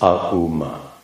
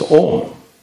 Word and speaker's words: Om: That is absolutely incorrect, to write in English letters A, U, MA. So if you write it like that, you should pronounce it AUM. But it's not Aum, it's Om.